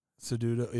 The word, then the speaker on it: Cejudo: Yeah, Jones has been training the last several days at Fight Ready MMA in Scottsdale, Arizona, under Henry Cejudo. Is that how you say it? – Cejudo, yeah.